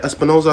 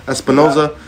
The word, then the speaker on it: Espinosa: Espinosa, right? Espinosa, I said it right? – You got it. – Espinosa.